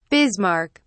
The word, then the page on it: bismarck: British English